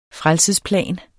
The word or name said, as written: frelsesplan